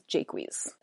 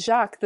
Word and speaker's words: Jaques: I've heard it as an A more often, but it scans in two syllables. It scans Jaques. I'm going to find the line to prove it. Indeed, my lord, the melancholy Jaques grieves at that. If it's Jacques, the scan is all off.